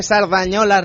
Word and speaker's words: Cerdanyola: Muy buenas noches, bienvenidos una noche más a Arcadia Gamers, el programa de videojuegos de Cerdanyola Radio, 105.3 FM, lo he dicho bien, bien.